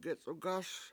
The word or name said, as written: gec7ugAS